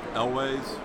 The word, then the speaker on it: Elwes: Elwes.